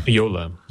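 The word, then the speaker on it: Jolla: I watched the video and I forgot now how you pronounce it. Is it Jola? Hola? – It's Yola.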